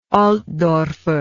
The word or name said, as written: altdorfer